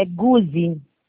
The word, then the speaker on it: Guzi: Como pronuncia seu nome? Desculpa. – É Guzi.